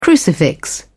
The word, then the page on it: crucifix: British English